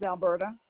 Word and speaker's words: Alberta: My last name is Rucker, R-U-C-K-E-R. – That first and last name? – First name's Alberta. – Can you, Alberta is spelled A-L-B-E-R-T-A? – Yes, uh-huh.